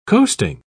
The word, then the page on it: coasting: British English